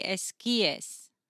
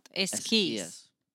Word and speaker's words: esquíes: We say one ski, but if you're talking about both of them, the plural would be esquíes, not esquís.